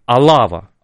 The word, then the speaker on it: Алава: И за это Наварра и Алава при франкизме, да, мы всегда говорим про что франкизм это такой унифицирующий, но Алава, прошу прощения, там ударение на первую букву,